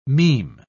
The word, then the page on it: meme: British English